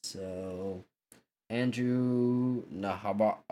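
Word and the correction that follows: Nembhard: I cannot say his name. Andrew makes 2-point layup. The Celtics change Al Horford. – It's a Nimbard. Andrew Nimbard.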